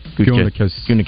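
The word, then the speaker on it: Gutekunst: Is it Gutekist? Is that how you say his name? Gutekist? I think. – Gutekist.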